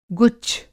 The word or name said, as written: गुच्छ